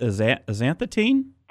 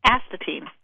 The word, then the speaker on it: astatine: Explain about those, because I know there's chloride, fluoride, bromide, and is it pronounced azanthetine? – Astatine.